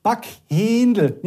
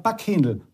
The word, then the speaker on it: Backhändel: Backhändel, nicht so wie du, sondern Backhändel. Mit kurzem E aussprechen.